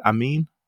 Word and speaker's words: Amin: Is it Amin? – It's Amin.